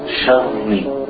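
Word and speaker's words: شرّ: The ra, you want, if you can pronounce it twice, sharri. Sharri? Sharri. Min sharri.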